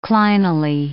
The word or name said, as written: clinally